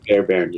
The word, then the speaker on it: Fairbairn: Am I saying it's Fairbairn? Is that how you say it? – That's how you actually say it. – Okay. – But everyone just called him Bruce Fairbairn. – It's the first, exactly, Fairbairn, you said it right.